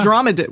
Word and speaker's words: dromedary: The dromedarius? Did I say that right? – Still funny. – Oh, the dromedary? – Uh-huh. – The dromedary. Yeah.